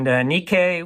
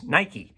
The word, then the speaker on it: Nike: And Nike was the Greek goddess of victory. And most of you don't recognize that pronunciation, but you recognize Nike, the shoe and apparel manufacturer.